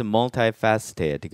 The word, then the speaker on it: multifaceted: 왜냐하면 강세가 multi 이쪽에 있기 때문에 faceted라고 읽게 되겠어요. 그래서 multifaceted 그러면 면이 다양하게 있는 해서 다면적인이란 뜻이 되겠습니다.